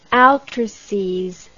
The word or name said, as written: altrices